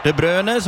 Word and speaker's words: De Bruyne: De Bruyne,